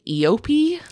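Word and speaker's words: Eopie: Not really, but Obi-Wan Kenobi does have an Eopi. – Yeah. – Is that how they say it? – Yep. – In the fandom? – Yep.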